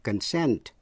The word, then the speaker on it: consent: Hai từ nữa là concern, C-O-N-C-E-R-N, là quan tâm hay là liên hệ đến, và consent, C-O-N-S-E-N-T, nghĩa là thỏa thuận. Dù là danh từ hay động từ đều nhấn vào vần nhì và phát âm y hệt nhau.